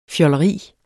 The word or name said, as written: fjolleri